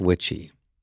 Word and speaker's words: Witchy: His name is Lance Witchy, pronounced like Witchy Woman.